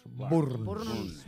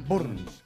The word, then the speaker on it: Burns: Burns. – Como si uno arrancara la moto. Burns.